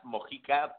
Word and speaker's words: Mojica: it's pronounced Mojica, but it's fine.